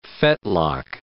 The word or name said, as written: fetlock